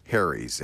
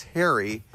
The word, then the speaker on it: Harry's: She said, Leo, you say it like it's Harry, like H-A-I-R-Y, but it's Harry.